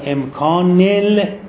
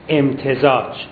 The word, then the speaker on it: امتزاج: و الان این استدلال اینه، ببینید من میخونم، عدم و امکان امکان لامتزاج، این تلفظش رو میدونید معمولا ما طلبا میخونیم عدم و امکان ال، بگید امتزاج، میخوام دعاها رو درست بخونیم.